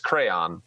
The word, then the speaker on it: crayon: So I just clicked crayon, and Fitz will have to do this to add this in, but it is how I pronounce it. It's crayon. So Google says that I am correct.